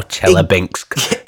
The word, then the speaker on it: Chelyabinsk: I think that's how they pronounce it as well, Chelyabinsk. – Exactly like that.